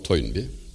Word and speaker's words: Toynbee: İngiliz tarihçi Arnold Toynbee 1921'de gelip 51 yıl Osmanlı kütüphanelerinde ders çalışan bir adam.